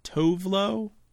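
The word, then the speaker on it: Tove Lo: but Tovlo? – Yep.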